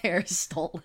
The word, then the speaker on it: Aristotle: I always like the people who say that they, they see the name Aristotle and they want to pronounce it like Chipotle. Aristotle.